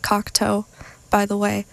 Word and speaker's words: Cocteau: Even though I know how it's pronounced, I know it's pronounced Cocteau. I think. No, it is. It is. But somehow, just the nerves get to me. I say Cocteau. I'm like, oh no, it's not right.